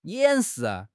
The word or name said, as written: 掩饰